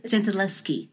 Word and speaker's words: Gentileschi: Bloodwater Paint is about the painter, and please correct me if I pronounce it wrong, Artemisia Gentileschi? – Gentileschi. – Very close.